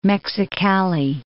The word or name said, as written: Mexicali